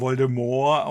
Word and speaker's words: Voldemort: Also nachdem erstmal hier Harry Potter und äh, Lord Voldemort, der offenbar Voldemort ausgesprochen wird, das T ist stumm.